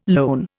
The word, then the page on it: lone: Indian